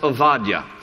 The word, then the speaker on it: Obadiah: There's no B in the Hebrew alphabet. It's a V. And so in Hebrew, his name is pronounced Obadiah.